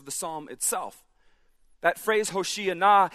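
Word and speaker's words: Hoshea na (הושיעה נא): If you know Hebrew, you're yelling, "Hoshea na." That can sound like something else, so you have to pronounce it carefully. Hoshea na.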